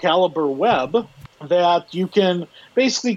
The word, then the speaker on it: Calibre: That I believe it's pronounced Calibre. – Yeah, no, you're right.